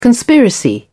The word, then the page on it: conspiracy: British English